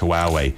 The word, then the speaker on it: Huawei: So, Huawei, Huawei, it's pronounced Huawei, Ascend G7.